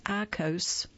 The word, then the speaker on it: IQOS: The product called IQOS, pronounced IQOS,